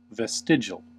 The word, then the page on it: vestigial: American English